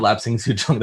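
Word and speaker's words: Lapsang souchong: And I horribly mispronounced this tea. I don't remember even how I thought it was pronounced, but it definitely was not lapsing souchong the first time I said it. Was quickly corrected and given a glass, and I instantly tasted that nice, beautiful, warm, smoky notes that people who love Islay scotches talk about.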